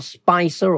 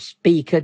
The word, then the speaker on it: Spica: And it's passing the star Spicer or Speaker, depending on how you want to pronounce it.